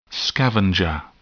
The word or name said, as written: scavenger